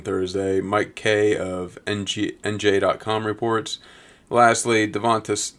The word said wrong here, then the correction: DeVonta: Devontae Smith. I know it's spelled Devonta, but he goes by Devontae Smith.